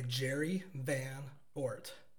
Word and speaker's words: Jerry Van Oort: Jerry Van Oort.